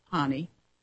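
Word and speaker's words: Pany: It says, during the 62nd annual meeting in the southern German city of Augsburg, Franz Pani, I guess it's P-A-N-Y, I'm going to pronounce it Pani, the leader of the Sudeten German organization, effectively demanded that Prague apologize for expelling the ethnic German populace.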